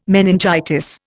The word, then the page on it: meningitis: Indian